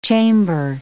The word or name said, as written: chamber